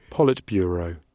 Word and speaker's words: Politburo: so, right, and the word appears to be Politburo, and now, out of because uh, I wanted to check how you pronounce it, I, have got it here. So here we go. Politburo. Politburo.